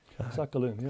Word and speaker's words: Sakhalin: Sakhalin, yeah. – Sakhalin, yeah. – All right, Greenland and South Georgia Islands. So let's talk about some of those. Let's start with Kamchatka and Sakhalin.